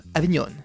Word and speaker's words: Avignon: que l'on prononce Avignon.